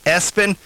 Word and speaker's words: Espen: Also the name ESPN, pronounced Espen.